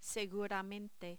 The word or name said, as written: seguramente